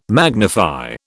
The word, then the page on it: magnify: British English